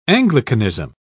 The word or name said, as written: anglicanism